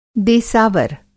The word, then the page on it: देसावर: Hindi